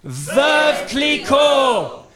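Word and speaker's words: Veuve Clicquot: On three, I think this time we should say veuve cliquot, just to kind of up the stakes a little bit. Are you ready? One, two, three. – Veuve cliquot!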